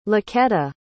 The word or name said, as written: laquetta